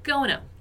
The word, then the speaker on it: going to: Porque eu já falei disso na aula passada, é a pronúncia, a maneira como a gente fala o going to. Que, como você já viu e ainda deve se lembrar, é gonna, gonna, gonna.